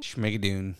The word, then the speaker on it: Schmigadoon: Speaking of Saturday Night Live, I'm also watching, and I think I'm going to say it right, Schmigadoon? – Schmigadoon. – Yeah, Schmigadoon.